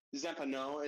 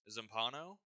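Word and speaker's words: Zampanò: I would say it's like the House of Leaves proper, which is by Zampano. I hope I'm saying that right. – I think it's got the accent mark slanting down, so wouldn't it be Zampano? – You know more than me, I'm sure.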